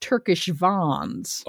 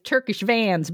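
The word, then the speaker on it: Turkish Van: She is I am being told that it is pronounced Turkish Vons. – Oh, please. Not in Midwest. – Yeah, no. In the Midwest is Turkish Vans, baby.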